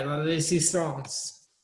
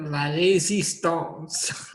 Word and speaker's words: La Résistance: You see how I said that? La Résistance. – Well, you are a Canadian, so I guess. And you know French, so you would be able to pronounce that properly.